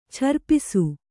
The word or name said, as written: ಛರ್ಪಿಸು